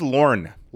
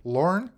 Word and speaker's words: Laurent: You said that maybe Laurent, how do you say he prefers Lorne. – Lorne? – Yeah, believe it or not.